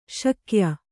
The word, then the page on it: ಶಾಕ್ಯ: śakya